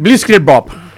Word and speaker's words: Blitzkrieg Bop: Blitzkrieg Bop.